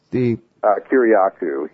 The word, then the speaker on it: Kiriakou: Well, and this guy, how do you say it, Kiriakou? – Kiriakou. – Kiriakou, huh? I'm sorry.